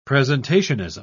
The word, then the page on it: presentationism: British English